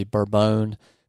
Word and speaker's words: Bourbon: T-Y-P-I-C-A, or you'll see Bourbon, which is spelled like bourbon, but Bourbon.